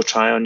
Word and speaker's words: Tryon: Have I said that wrong? – No, Joe Tryon, yeah.